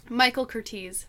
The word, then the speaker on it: Michael Curtiz: Michael Curtiz.